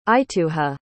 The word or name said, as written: ituha